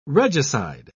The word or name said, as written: regicide